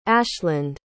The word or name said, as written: ashland